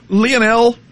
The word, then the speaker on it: Lionel: Lionel, notice I said it correctly.